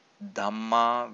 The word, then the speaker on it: Dhamma: In order to fully understand what we discussed above, one must have a good idea about Dhamma with a long A, which are really Kamma Bija.